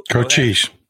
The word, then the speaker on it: Cochise: Uh, the board members in Mohave um, should go ask their buddies um, it, what, what is Cohese? Is that how you say it? Co- Cochise. Cochise. Cochise.